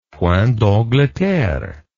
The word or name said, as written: point d'angleterre